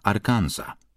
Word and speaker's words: Arkansas: Clinton urodził się w najbiedniejszym i najprymitywniejszym wówczas stanie USA, Arkansas. Co ciekawe, jego nazwa wywodzi się z języka francuskiego, stąd nieme S i inna wymowa niż w przypadku Kansas.